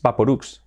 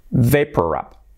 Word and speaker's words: Vaporub: Es Vicks, Vicks Vaporub, Vaporub, Vicks Vaporub. El Vicks Vaporub sería Vicks Vaporub, Vaporub. Vaporub es vapor, vapor, y luego rub, es un juego de palabras, Vaporub. Vicks Vaporub. Wow, toda la vida pronunciando el Vicks Vaporub, el Vicks Vaporubs, pues al final es Vicks Vaporub. Vaporub, acabado en B.